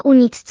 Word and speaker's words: Unix: Co więcej, wymawiane po angielsku Unix brzmiało identycznie jak Eunuchs, czyli Eunuchowie, co dodawało nazwie dodatkowy, nieco pikantny humor.